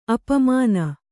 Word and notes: ಅಪಮಾನ: apamāna

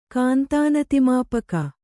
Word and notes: ಕಾಂತಾನತಿಮಾಪಕ: kannada; kāntānatimāpaka

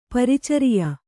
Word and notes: ಪ್ರಾಚಾರ್ಯ: prācārya; Kannada

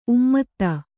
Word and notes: ಉಮ್ಮದ: ummada

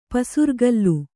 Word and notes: ಪಸಿರ್ಗಲ್: kannada

